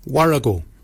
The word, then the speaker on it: worragol: Barken is spelled B-A-G-I-N-Y. Barken. Barken is your beard. The upper mouth, we say warrigal. Warrigal. Warrigal is spelled W-O-R-R-A-G-O-L.